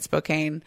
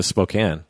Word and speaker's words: Spokane: I wonder if he actually went to Spokane. – Maybe. Spokane. I always say Spokane. Spokane. – Yeah. – It looks well, he might have said Spokane because it rhymes with cocaine.